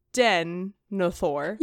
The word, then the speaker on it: Denethor: but the stress is still on the first syllable, the denethor.